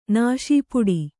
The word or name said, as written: ನಾಸೀಪುಡಿ